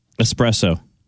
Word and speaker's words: espresso: Espresso. – What'd you say? – Espresso. – Espresso. – Yeah, with no X. – Right. I think that's the correct way to say it.